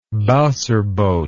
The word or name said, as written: bowser boat